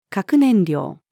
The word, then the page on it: 核燃料: female